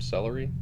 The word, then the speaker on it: Sellerie: Yeah, right now I'm looking at a seat made by a French company, Top Celery. – Oh, interesting. – But it's spelled S-E-L-L-E-R-I-E. Yeah. But apparently it's pronounced like the flavorless vegetable.